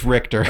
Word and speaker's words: Richter: is it Richter or Richter? – No, it's Richter. – All right. – Richter. They say his name in the game multiple times.